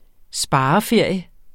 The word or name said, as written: spareferie